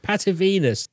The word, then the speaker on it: Patavinus: Well, the first one is about some phantom ships from a historian called Titus Livius Patavinus. I did it. Patavinus.